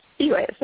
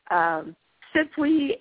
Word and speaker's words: Spiwe: Yeah, because I get, you know, it's pronounced Spiwe. So I get Spiwe, Spiwe, Sipwe, and I'm just like, okay, why didn't you just ask? – Exactly.